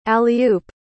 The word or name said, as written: alley-oop